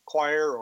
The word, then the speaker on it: coir: Another fiber that I suspect many listeners have never heard of is coir, spelled C-O-I-R. It is a roping fiber made from the husks of coconut. About 1 million tons of coir or coir, it's pronounced differently in different places, are produced each year, primarily in India.